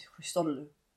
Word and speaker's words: Christodoulou: I think it's Christodoulou.